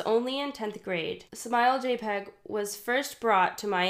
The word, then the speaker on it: JPEG: Okay, so most people call that JPEG.